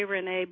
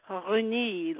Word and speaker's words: René: How do the French pronounce it? – Well, I'll probably mess it up, but they pronounce their R's a little differently, so they say like René, like that.